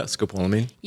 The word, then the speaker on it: scopolamine: Yeah, scopolamine.